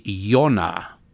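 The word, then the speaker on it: Yonah (יונה): And so uh, Moses is really Moshe, and Jonah is really Yonah and Noah is really Noach.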